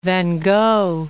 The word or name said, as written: Gogh, van